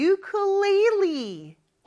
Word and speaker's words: ukulele: I play the ukulele. 어? 아까 우쿨렐레라고 했잖아요. 근데 발음이 좀 다르네요. 그렇죠? ukulele라고 합니다.